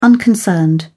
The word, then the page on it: unconcerned: British English